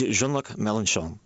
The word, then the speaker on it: Jean-Luc Mélenchon: And in France, you know, there's Jean-Luc Mélenchon, who is the candidate of the Left Front. And that was good. Say that again. – It's Jean-Luc Mélenchon.